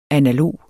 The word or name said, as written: analog